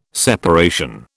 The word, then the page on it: separation: British English